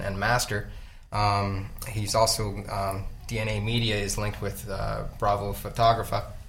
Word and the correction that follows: Bravo Fotografia: I said that wrong. Anyway, Bravo Fotografia, I believe.